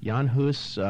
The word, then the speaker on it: Jan Hus: The 100 years preceding had seen, Wycliffe, Jan Hus, is that how you pronounce yes. – it? – Yes. – Jan Hus,